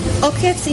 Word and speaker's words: Okets: Okets, it's like when a bee is on top of your body.